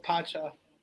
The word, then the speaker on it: Pacha: Pancha? There's an N? Pacha. I must have misspelled it. – No, it's Pacha. – Pancha. – P-A-C-H-A, yeah.